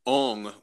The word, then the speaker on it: ong: And in this chant, is the first syllable om or ong? – It is ong, which is the same as om.